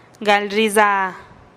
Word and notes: gal riiza: TdVZ

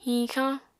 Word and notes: hiñxañ: Apatani

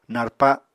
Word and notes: narpa: Apatani

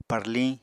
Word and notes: parliñ: Apatani

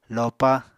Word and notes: lopa: Apatani

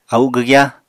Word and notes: au gwgyá: apatani

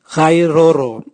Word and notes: gairoro: Raga

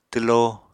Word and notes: twlo: apatani

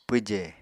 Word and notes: pwje: Apatani

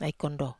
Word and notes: ai kondo: Wamesa

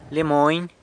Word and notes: Lia Mony: sanlucasquiavini

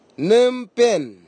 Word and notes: nimpen: Nafe; Tanna

